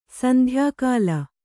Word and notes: ಸಂಧ್ಯಾಕಾಲ: sandhyākāla

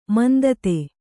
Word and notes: ಮಂದತೆ: mandate

